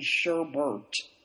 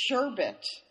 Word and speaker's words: sherbet: I want to say, for some reason, sherbert. Mm-hmm. I want to put an extra R in there, but that is not correct. – That's not correct? – No, it's sherbet.